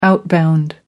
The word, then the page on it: outbound: American English